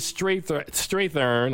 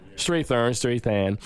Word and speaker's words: Strathairn: I thought that Gugu Mbatha-Raw, who anchors this story, Lorraine Toussaint, Sanaa Sidney, David Strathairn, I think his name is pronounced, Strathairn, is pretty good in this film.